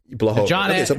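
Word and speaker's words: Błachowicz: How do you say it? It's Blachowicz. – Blachowicz? – You say Blachowicz.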